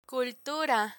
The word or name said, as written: cultura